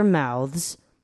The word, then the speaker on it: mouths: simply opening 就有点像 simply o，把那个 p l y 跟 o 粘在一起，simply opening their mouths。好，这边有一个难的喽。 Mouth 加 s，你就把它念成 mouths，这个大家要多练习。